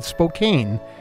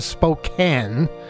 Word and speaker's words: Spokane: Which on the other radio show, I made the mistake. And I admit it was a mistake of pronouncing it Spokane instead of Spokane.